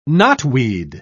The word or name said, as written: knotweed